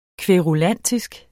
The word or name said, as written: kværulantisk